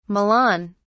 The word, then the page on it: milan: British English